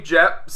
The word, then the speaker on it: Jepp: So he had a little person whose name was Jep. – Jep. – J-E-P-P. – J-E-P-P. Jep. – Yep. Who Tycho Brahe believed had... – That's how it's pronounced.